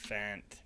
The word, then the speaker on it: Fant: Did I say that right? Fant. – Fant. Noah Fant.